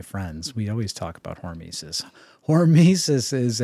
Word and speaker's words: hormesis: it's hormesis, right? – Hormesis.